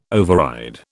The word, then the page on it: override: British English